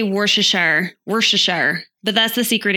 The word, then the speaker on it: Worcestershire: How do you say this word? It's Worcester. – It's Worcestershire. Worcestershire sauce.